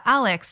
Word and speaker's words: ALEKS: Yeah, Alex. So that's spelled A-L-E-K-S. It's actually an acronym that stands for Assessment and Learning in Knowledge Spaces.